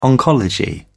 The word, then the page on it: oncology: British English